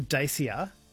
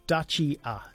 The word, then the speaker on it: Dacia: We were calling it the Dacia. Graham's texted in to correct our pronunciation. So it's pronounced Dacia.